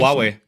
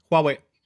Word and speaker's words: Huawei: Wie würdet ihr Huawei aussprechen? – Huawei. – Na gut, das habe ich schon gesagt. – Huawei. – Genau, so ja, heißt es genau, nämlich. Ja. Huawei.